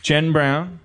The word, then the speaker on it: Jen Brown: Jen Brown. All right. Jen Brown. Her name rhymes with Harmontown.